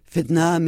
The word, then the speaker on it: fitna: And you talk about the bloody split, and there's a word that I won't pronounce right, fitna.